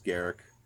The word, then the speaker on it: Garruk: I'm not saying Garruk. It's Garruk. It's pronounced Garruk. So